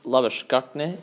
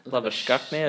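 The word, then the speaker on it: Labuschagne: Manus Lobashkukne, he's in the squad. – How do you say that? – Lobashkukne, I believe that's how you pronounce it in... – Lobashkukne.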